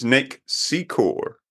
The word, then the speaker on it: Secor: has come back to us and actually told us how to pronounce his name properly. – Oh, yeah. – Mm-hmm. And it's Nick Secor, which is way the hell cooler than anything we could have done.